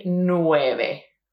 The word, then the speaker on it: nueve: last E sometimes in certain words, and so you may want to say nueve o algo así. It's not like that. Yeah, we're going to say nueve, nueve. So we pronounce that last E there.